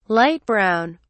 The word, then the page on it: light brown: British English